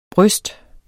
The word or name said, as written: bryst